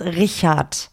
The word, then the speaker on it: Richard: Entonces, aquí el CH de eh, Richard es es äh, suave. Entonces es Richard.